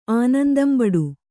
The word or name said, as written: ಆನಂದಂಬಡು